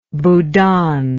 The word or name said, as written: bhoodan